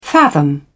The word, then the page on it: fathom: British English